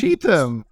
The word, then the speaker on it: Cheetham: I'm sorry if I'm not pronouncing this correctly. Is that correct, Julie? – It's Cheetham. Close enough. – Cheetham. Okay, well, let's use that.